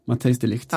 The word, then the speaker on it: Matthijs de Ligt: Matthijs de Ligt.